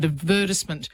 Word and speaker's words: advertisement: But advertisement was the one that was recommended when the word first appeared. And it is on the third last syllable, I think, which is where you expect the stress to fall.